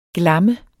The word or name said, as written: glam